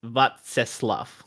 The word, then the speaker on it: Václav: Like I could get like it's I'll try and it's Václav. And I probably went a little bit too close to an F on the end there,